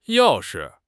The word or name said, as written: yao4 shi5